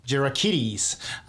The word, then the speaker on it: Gerakiteys: Gerakiteys.